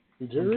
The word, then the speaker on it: Ujiri: Speaking of the Raptors, Massey Urie, is that how you pronounce that name? – Ujiri? – Canada, yeah. – Massey Ujiri, yeah.